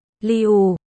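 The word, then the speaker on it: liu (六): Trong tiếng Trung số 6 được viết là lưu và cách phát âm là liu.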